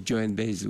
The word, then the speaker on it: Joan Baez: Vous ne le prononcez pas pareil, Baez. – On dit Joan Baez, oui.